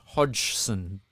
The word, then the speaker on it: Hodgson: Josh Hodgson, the good Hodgson.